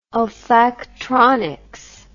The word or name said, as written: olfactronics